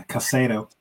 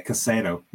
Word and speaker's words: Caicedo: Moisés said Caicedo? – Yeah, Caicedo, yeah. That's what it's called, Caicedo.